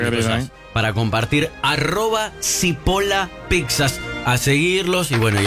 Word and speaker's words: Cipolla: Cipolla se escribe con C y con... de, con Elie, o sea, Cipolla. – Cipolla, ¿no? Cipolla. Cebolla en italiano.